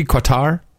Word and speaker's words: Qatar: They say you can pronounce it either way, but if you listen to a Qatarese speak it, they say it in some really kind of a guttural way that's Cutter, different than both.